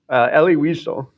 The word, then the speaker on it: Elie Wiesel: So Wiesel, Elie Wiesel.